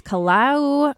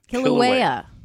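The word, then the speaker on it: Kīlauea: Hawaii's Kilauea volcano shoots lava 330 feet into the sky. – Kilauea volcano yep.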